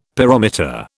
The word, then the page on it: pyrometer: British English